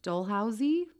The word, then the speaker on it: Dalhousie: Dalhousie? It's spelled D-A-L-H-O-U-S-I-E. So Port Dalhousie.